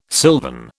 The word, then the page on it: silvern: British English